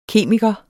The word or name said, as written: kemiker